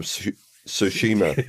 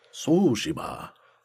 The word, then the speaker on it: Tsushima: My number two, Ghost of Tsushima. – Ghost of... Damn, this word's hard to pronounce. – Just forget there's a T.